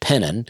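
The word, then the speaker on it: Penton: That's P-E-N-T-O-N, pronounced Pennon. And then Lafette, which is L-A-F-A-Y-E-T-T-E, spelled like Lafayette.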